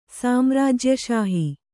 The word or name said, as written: ಸಾಮ್ರಾಜ್ಯಷಾಹಿ